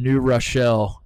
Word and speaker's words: New Rochelle: We have New York City, Catholic Church, Adidas, and Rick Pitino are all four coming together in New Rochelle.